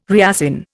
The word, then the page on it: reassign: Indian